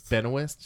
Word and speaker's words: Benoist: Benoist.